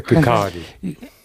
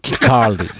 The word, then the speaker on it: Kirkcaldy: pronunciado en Kirkcaldy, en Escocia, en 2005, el discurso de Greenspan. – Se pronuncia Kirkcaldy. – Kirkcaldy.